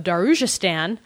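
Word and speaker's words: Darujhistan: Esselmont's fourth novel set in the Mazalan world returns to us the gaslit streets of Darujistan. Ha ha, I said it right this time.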